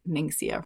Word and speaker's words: Ningxia: She's a homeschool mama that engages in young living essential oils, and she sent me a package of something called Ninxia Red. Have you heard of it? I don't know that I'm pronouncing that right. It is Ningxia.